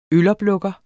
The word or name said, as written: øloplukker